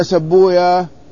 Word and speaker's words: سيبويه: قال وقال مكي بن أبي طالب في تفسيره وليست هذه الزيادة توجب أن تكون الوسطى غير العصر، لأن سبويا حكى مررت بأخيك وصاحبك، ها؟